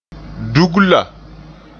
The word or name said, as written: dugla